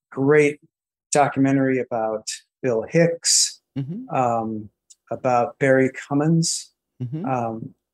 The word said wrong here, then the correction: Crimmins: Crimmins, I believe.